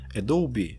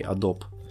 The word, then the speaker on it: Adobe: Однако также какое-то время назад Adobe, а именно так произносится название этого производителя ПО, я сам раньше не знал, а не Adobe, Adobe начали постепенный процесс введения Photoshop нейрофильтров.